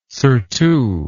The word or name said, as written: surtout